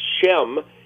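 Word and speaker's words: Shem (שֵׁם): In Hebrew, the word name is Shem. It's spelled Sheen Mem.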